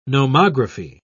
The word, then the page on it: nomography: British English